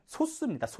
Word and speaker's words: 소수: 정확한 발음은 소수입니다. 소수. 우리 0.1, 0.2 이런 소수와 구분을 짓자는 의도에서 발음상 소수라고 부르는데요.